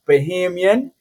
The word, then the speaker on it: Bahamian: And Wit, to correct you on your pronunciation, it's Bahamian.